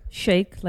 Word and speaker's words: Shaikh: Shaikh, like shake and bake.